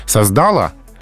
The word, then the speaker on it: создала: И именно поэтому в женском роде здесь э, ударение не на последнем слоге, это грубая ошибка. То есть сказать, например, создала, угу. а, вместо создала. Это ошибка.